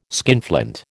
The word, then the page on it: skinflint: American English